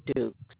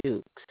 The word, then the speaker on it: Dukes: Okay, my name is Gloria Dukes. – Can you spell that first and last name for me? – Yes, G-L-O-R-I-A, Gloria. The last name is Dukes, D-U-K-E-S.